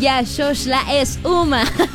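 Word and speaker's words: Ya Shoshla Es Uma (Я сошла с ума): Matcher, justo antes de convertirse en un hit mundial, nuestros artistas Match The Tattoo sacaron esta canción que se llama, se pronuncia más bien, Ya Shoshla Es Uma.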